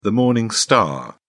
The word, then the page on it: morning star: British English